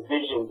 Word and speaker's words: DVSN: Now that you say that, the Division, it's spelled weird, right? It's spelled like DVSN, right? – Yeah, DVSN.